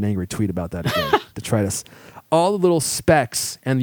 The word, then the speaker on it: detritus: And that's what creates the magic of microfiber is the surface areas, all the little hairs that pick up deritrus. Did I say that right? Someone corrected me last time and I had to go look it up. I think I got detritus. – Detritus. Thank you.